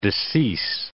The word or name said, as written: decease